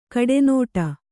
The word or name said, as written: ಕಡುನುಡಿ